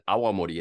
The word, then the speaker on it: awamori: So Sochu, S-H-O-C-H-U, Sochu. And then Awamori, as you said before, A-W-A-M-O-R-I. That's it. It's really not any trickier than that. So if you stay true to those vowel sounds, you're going to be understandable about 99% of the time.